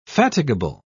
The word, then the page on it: fatigable: British English